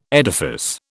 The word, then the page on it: edifice: British English